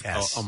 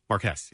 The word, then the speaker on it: Marquess: How do you say that? Marquess? Marquet? – Marquis. – Marquesas. – Marquesas. Okay. – Which one? – Marquess. – Completely schooled. – What is it? – Marquess.